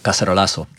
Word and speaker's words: Cacerolazo: how do you say it in the Venezuelan one, the Caracola? No, the Carazola? – Hola, el Cacerolazo.